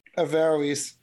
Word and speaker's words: Averroes: Averroes? – Averroes.